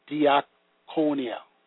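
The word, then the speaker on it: diakonia (διακονία): The deacon, the Greek word is diakonia, pronounced diakonia, meaning to be an attendant or to wait upon.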